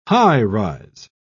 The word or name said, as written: high-rise